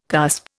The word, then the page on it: cusp: Indian